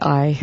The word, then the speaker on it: Ai: Achan, they start out with Achan, and then Joshua sent men to Jericho, from Jericho to Ai, or really Ai, that's how you should pronounce it, to the east of Bethel.